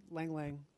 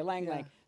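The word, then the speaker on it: ylang-ylang: Is it Lang Lang? – Oh, yeah. I've always said Lang Lang. – Okay, Lang Lang.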